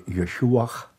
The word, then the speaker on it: Yeshua: To give an example, when pronouncing the blessing during the service, they would no longer say Jesus Christ, they would say Yeshua of Nazareth. So pronounce the name of Jesus in the Hebrew way.